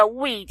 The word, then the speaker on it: Wii: 就像当年的 Wii 哦，这个 WII，其实它的名称出来的时候，它就是在号称呢，那个 Wii 就跟 WII 呢就跟 WE 就是 We 我们哦的这个。发音很像，就是在主打说呢，我们可以全家一起玩乐，无论老少，无论是年纪大小，全部都可以老少咸宜，全家同乐的概念。